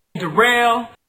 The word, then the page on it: Darrell: pronounced like Durrell